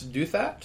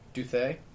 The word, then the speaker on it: Douthat: As Ross Douthat, is that how you would pronounce that one? Sure. – It's either that or Douthat. There you go. I like the French pronunciation.